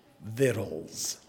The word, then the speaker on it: victuals: Another one, we have struggles with this one, V-I-C-T-U-A-L-S. V-I-C-T-U-A-L-S. You will stand up in church and say victuals. No. V-I-C-T-U-A-L-S means food, and it's pronounced vittles.